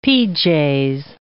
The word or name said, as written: pj's